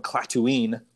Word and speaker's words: Clatooine: They're from Clatooine, which rhymes with Tatooine, oddly enough.